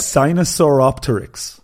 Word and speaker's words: Sinosauropteryx: Sinosauropteryx.